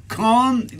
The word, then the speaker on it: Cannes: Cannes diye yazılıyor ama Cannes diye okunuyor. Enteresan değil mi? – Evet, biz de milletvekilimize hoş geldin diyoruz buradan.